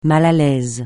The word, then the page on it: mal à l'aise: francais